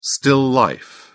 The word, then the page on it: still life: British English